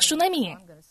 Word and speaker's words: Шунами: И здесь говорится о Сунамитянке. Но на самом деле это произносится как Шунами.